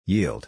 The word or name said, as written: yield